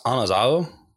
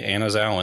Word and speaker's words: anazao: So it's technically pronounced anazao because there isn't like an eh sound in like the Koine Greek, mm-hmm. which is really difficult. So we pretty much all say anazao anyway.